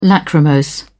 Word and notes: lachrymose: British English

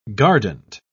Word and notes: guardant: British English

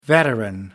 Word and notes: veteran: American English

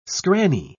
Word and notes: scranny: British English

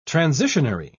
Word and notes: transitionary: British English

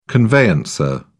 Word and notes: conveyancer: British English

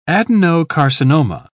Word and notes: adenocarcinoma: British English

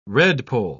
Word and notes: redpoll: British English

